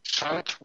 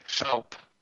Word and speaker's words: Schaub: Schaup with a B. Schaup. – Schaup. Friedman Schaup. – Schaup.